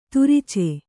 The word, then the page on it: ತುರಿಚೆ: turice